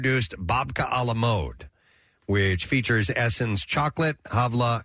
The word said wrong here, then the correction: halva: Halva, I guess. – Halva? – And H-A-L-V-A is how it's spelled.